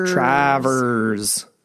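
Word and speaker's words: Traverse: And let's pronounce this properly here. – Say it with me. Travers City.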